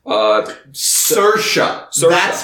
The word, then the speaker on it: Saoirse: Charlize Theron from Bombshell, Scarlett Johansson from Marriage Story, Saoirse—that's how you pronounce it.